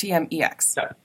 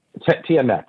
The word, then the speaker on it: TMX: Am I right that it's pronounced TMX or is it pronounced TMEX? – TMX. – TMX, I thought so.